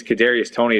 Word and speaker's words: Kadarius Toney: It might be, I believe it's Kadarius Toney is how you pronounce it.